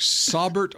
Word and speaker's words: Saubert: Would you like the correct pronunciation of Eric, S-A-U-B-E-R-T? – You know it now? – According to the Denver Broncos pronunciation guide for him, Saubert. – Saubert. – Yeah, that sounds smart. – Eric Saubert. Saubert. – I bet it's Saubert, but they've got the emphasis on the Bert.